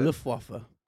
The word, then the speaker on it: Luftwaffe: Luftwaffe.